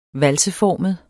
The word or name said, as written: valseformet